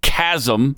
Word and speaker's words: chasm: By the way, the word is actually pronounced chasm, but John Kerry doesn't know that.